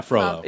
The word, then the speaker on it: Frollo: Yeah, Frollo.